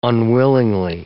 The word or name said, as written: unwillingly